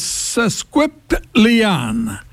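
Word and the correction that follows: sesquipedalian: I guess that's a horrible pronunciation. – It is? – How is it said correctly, Kevin? – Sesquipedalien.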